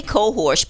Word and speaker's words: cohosh: I say Cohosh, but there's no R in it. It's Cohosh, probably like you said. – So the Black Cohosh, probably. But I don't just drink straight Black Cohosh because that affects a lot of female stuff.